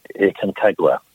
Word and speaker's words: Aconcagua: It's Aconcagua is the name of the mountain.